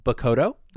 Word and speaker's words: Bakudo: It's actually pronounced Bakudo, by the way. – Bakudo. – I'm probably going to mispronounce that some more, but it is Bakudo.